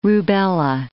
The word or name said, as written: rubella